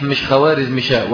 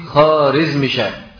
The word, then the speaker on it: خوارزمشاه: وعلى فكرة النطق الصحيح مش خوارزمشاه ولكنه إيه؟ خارزمشاه. الواو دي بيسموها الواو المعدولة في اللغة الفارسية، يعني في اللغة الفارسية بيسموها الواو المعدولة، لا تنطق، لا تنطق، ولكن احنا بنقولها لأن الناس تعودوا على ذلك، ولكن صح نقول إيه؟ خارزمشاه مش خوارزمشاه.